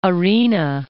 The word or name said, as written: Arena, Point